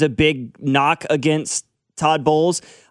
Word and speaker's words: Bowles: I think Bowles it's Todd Bowles, right? – Yep. – Bowles. I didn't know if I said that correctly.